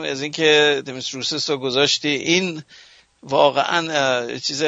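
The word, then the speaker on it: دمیس روسس (Ντέμης Ρούσσος): البته تو ایران ما میگفتیمش روسس. مثلا تلفظ درستش اون نیست، دمیس روسس اسمشه.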